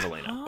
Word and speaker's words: Alana: It is spelled A-L-A-N-A, but it is pronounced Elena. Elena. Because, yes, up until I heard someone say it, I was reading Alana. Yeah. It is Elena.